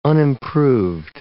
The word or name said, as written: unimproved